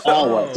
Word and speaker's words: Alwa: It's Alwa.